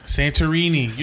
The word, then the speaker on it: Santorini: Santorini. You never been to Santorini? – You never been. – I said it in an Italian accent. – What's a Greece accent? – No, I've been to Santorini in my dream.